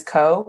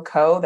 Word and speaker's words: Ko: So my name is Co, K-O, Co, that's it. I spell it so that y'all don't add any extra letters. literally, otherwise, I'm Cole, I'm Nicole, and I'm none of those things.